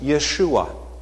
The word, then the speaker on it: Yeshua: Now, Jesus is a Hebrew name, and it's pronounced Yeshua.